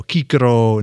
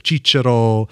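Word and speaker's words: Cicero: Every C is a hard C. Kaiser, you know, Kikero instead of Chichero.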